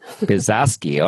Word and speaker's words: Biesalski: Biesalski, all right.